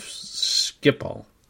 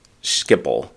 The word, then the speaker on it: Schiphol: I thought it was Schiphol. I have heard a K in there sometimes. But you apparently say it the right way, which is the Dutch way of Schiphol. – Schiphol.